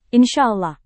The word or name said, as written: insya allah